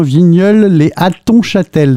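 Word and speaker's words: Vigneulles-lès-Hattonchâtel: C'est à, alors je prononce bien, Vigneulles les Hattonchâtel dans la Meuse. – Oui, c'est ça.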